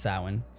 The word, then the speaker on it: Samhain: Halloween's origins date back to ancient Celtic festival, pronounced Samhain, spelled S-A-M-H-A-I-N.